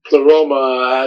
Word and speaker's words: Pleroma: Pleroma. What? Okay, so pronounce it one more time, and what does it mean? – Pleroma has to do with the whole symphony of celestial beings, so to speak.